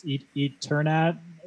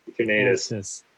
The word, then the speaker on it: Eternatus: The tallest Pokemon is also, oh man, I can't believe I have to keep saying this word, but it's Eternatus. – Like I'm just Eternal. – Keep butchering it. – Eternal. – Yeah, Eternatus.